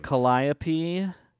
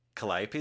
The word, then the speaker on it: Calliope: Isn't one of them Calliope? – I want you to say all of their names. – Calliope. – Calliope is the correct pronunciation. Well done.